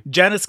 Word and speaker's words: Janusz: Janusz Kaminski.